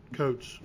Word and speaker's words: Coates: Coates.